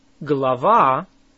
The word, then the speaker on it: Глава: How do you say a chapter? Глава. Глава. Say ва. Лава. Глава. It starts with a г or g sound. Say it again.